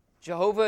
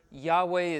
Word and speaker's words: Yahweh: Jehovah is not really the proper way to say that Hebrew word. Yahweh is closer to it, the best guess that we have at it.